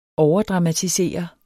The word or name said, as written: overdramatisere